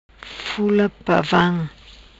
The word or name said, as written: Fula Pavam